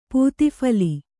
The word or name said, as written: ಪೂತಿಫಲಿ